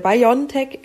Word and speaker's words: BioNTech: Also das ist äh, BioNTech übrigens. Tatsächlich ist es noch so ein bisschen mehr die Betonung auf dem N.